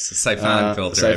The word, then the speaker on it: Siphon Filter: Siphon Filter, I believe.